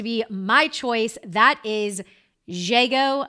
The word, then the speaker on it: Zhego: Z-H-E-G-O, Jago.